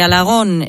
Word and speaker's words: Alagón: Alagón, con acento en la O. – Con acento en la O, eso es. Y con L, Alagón. Y bueno, entre Alagón, Alcorisa, la provincia de Teruel y Calabrés en Asturias, pues ahí discurre mi vida familiar y laboral, porque, ahí discurro.